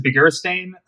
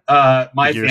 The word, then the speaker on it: Beguiristain: I pronounce it Beguiristain. Beguiristain. Family pronounces it some other way. I don't think anybody, well, because nobody in my family speaks Basque, so nobody knows.